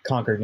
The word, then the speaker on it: Concord: I would be remiss if I didn't say uh, it is pronounced Concord, New Hampshire.